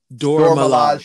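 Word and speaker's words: Dora Milaje: And by the way, when the how you pronounce it, the Dorma something? How do you say the name? – Dormilaje showed up.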